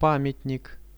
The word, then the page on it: pamiatnik: Russian